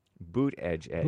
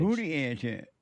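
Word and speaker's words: Buttigieg: Boot Edge Edge. – That's his name? – Yep, that's how you pronounce it. This is the correct pronunciation.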